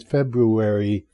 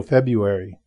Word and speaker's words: February: Anyway, as it turns out, the pronunciation of February, if you are really interested, is with the first R. So it's technically it's February, but the general accepted pronunciation, which is accepted by the standard dictionaries, is without the R there, the first R. So February.